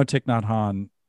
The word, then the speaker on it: Thich Nhat Hanh: How do you say that? Well, it's Thich Nhat Hanh.